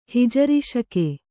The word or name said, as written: ಹಿಜರಿಶಕೆ